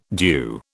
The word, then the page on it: DEW: British English